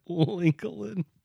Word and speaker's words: Lincoln: Lincoln.